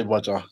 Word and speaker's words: Roger: Yeah, I guess I'm Hodger, because that's the pronunciation of his name.